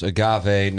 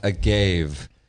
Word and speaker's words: agave: It's pronounced agave, not agave. It's agave, you leatherhead.